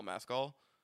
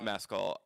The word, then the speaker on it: Mescal: Mescal. – How do you spell it? – M-E-S-C-A-L. – Oh, M-E-S. – Yeah. – Oh, like the booze.